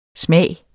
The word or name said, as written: smage